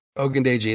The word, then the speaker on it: Ogundeji: Ogundeji. – Yeah, that's how he pronounces his last name.